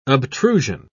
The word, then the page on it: obtrusion: British English